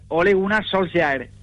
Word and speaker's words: Ole Gunnar Solskjaer: A ver, Ole Gunnar Solskjaer, pero tú dices, yo decía Solskjaer.